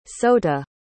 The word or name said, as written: sota